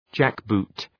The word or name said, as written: jackboot